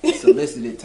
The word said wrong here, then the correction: Cicely: Then you gave her name wrong. Cicely Tyson. – Oh. – Okay. – Cicely.